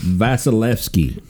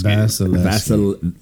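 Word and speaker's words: Vasilevskiy: What's his name? It's Vasilevsky. – Vasilevsky. – Vasilevsky. – Vasilevsky. It's like Vaseline. Vasilevsky.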